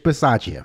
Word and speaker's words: Bisaccia: Bisaccia? – Rich Bisaccia.